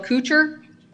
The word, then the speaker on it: Kucher: My full name is Michelle Kucher, K-U-C-H-E-R.